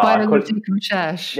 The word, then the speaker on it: Biologique Recherche: Like I use like retinols or like P50, you know, the brand Biologique Recherche. I can't really say it right. BR. – Biologique Recherche. – That's it. Yeah.